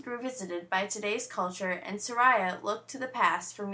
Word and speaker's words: Soraia: And we're from the band Soraya.